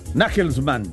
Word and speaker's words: Nagelsmann: Nagelsmann, ¿así se pronuncia? – Sí, o Nagelsmann.